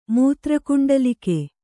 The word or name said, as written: ಮೂತ್ರಕುಂಡಲಕ